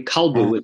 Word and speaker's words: Kalbu: So we had a dog whose name was very originally Kelev that he used to call Kalbu, because apparently Kalbu is how you say dog in either Akkadian or Ugaritic.